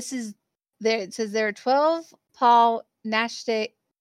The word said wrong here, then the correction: Naschy: I'm pronouncing this wrong. Naschy werewolf movies, but there can only be one on the list.